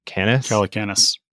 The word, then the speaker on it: Calacanis: Jason Calacanis? – Calacanis.